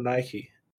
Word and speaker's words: Nike: Is it Nike or Nike? – It's Nike. – Is it? – Yeah, I think so.